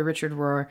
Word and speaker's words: Richard Rohr: I love Father Richard Rohr.